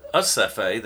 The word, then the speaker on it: Recife: Our last day in South America was at Recife, Brazil, on the northern coast. Interestingly enough, they call it Acefe. They have a silent R.